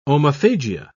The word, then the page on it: omophagia: British English